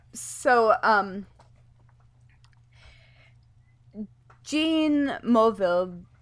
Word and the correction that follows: Mulvihill: I can't ever say his name correctly. Mulvihill. Mulvihill, Mulvihill, I think.